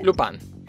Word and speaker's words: Lupin: Lupin.